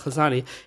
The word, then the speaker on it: lasagna: So when he is saying that line, real G's move in silence like lasagna, he is referring to the fourth letter G, which, if you say the word, is silent when you say it. It does not, you cannot hear it. You cannot hear the letter G.